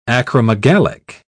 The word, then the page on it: acromegalic: British English